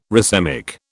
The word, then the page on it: racemic: British English